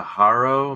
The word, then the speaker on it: Haro: So I bought a Haro. Am I saying that right? – Oh yeah, yeah.